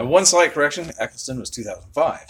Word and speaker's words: Eccleston: Am I saying his name right, Eccleston? – Yeah, yeah Christopher yeah, Eccleston. Yeah, Christopher Eccleston. – Yeah, in 2009, really should go back and explore, you know, the older episodes.